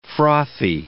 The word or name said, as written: frothy